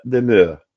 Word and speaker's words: demeure: Al comenzar la cavatina nos encontramos con la palabra demeure, que se escribe D-E-M-E-R-E, pero que se pronuncia demeure. La primera E suena como en el español, la segunda como la E francesa y la tercera es muda. Y la R se pronuncia como la R francesa.